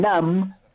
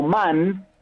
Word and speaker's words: nam: Sí, algunos pensabas que el NAM era como man, porque lo habían lo habrían puesto los ingleses. – Ah. – pero no, eso después fue como deshaciéndose y por eso se escribe N-A-M al final.